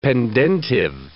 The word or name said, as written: pendentive